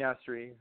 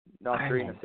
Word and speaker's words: Nasseri: Yeah, Armin Nasseri, or Nasseri, Nasseri, either or.